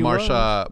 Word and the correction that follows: Mahershala: I enjoyed Mahershala.